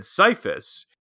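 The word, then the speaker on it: Scyphus: And it was sparked by your pronunciation of the name of the Sorcerer King, which I might have seen as Skyphus, but you said Sifus. – Yeah, I see the C as silent, like in science.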